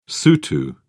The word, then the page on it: sotho: British English